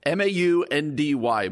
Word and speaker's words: Maundy: It's spelled M A U N D Y, Maundy, Thursday.